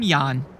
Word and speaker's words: Jan: I'm Jan. It's pronounced like yawn, but spelled with a J.